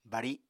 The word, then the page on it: bari: Apatani